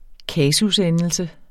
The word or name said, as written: kasusendelse